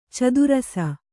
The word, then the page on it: ಚದುರಿಸು: cadurisu